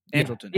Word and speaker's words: Andrelton: And Andrelton, Andrelton.